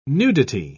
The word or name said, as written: nudity